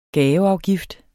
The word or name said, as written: gaveafgift